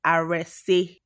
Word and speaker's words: Arese: Like, because you hear everything, Aris, Aresa. I've heard all kinds of like pronunciations, but I mostly don't mind.